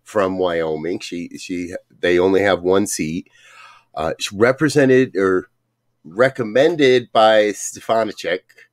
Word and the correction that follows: Stefanik: I know I'm saying that wrong, for her seat. – Stefanik.